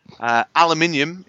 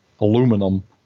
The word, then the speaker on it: aluminium: Aluminium is pronounced aluminium. – Aluminum.